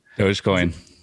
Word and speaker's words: Dogecoin: How, what, what's the, the the right way to say Doge? It? Dogecoin. – Doge. Dogecoin. Okay.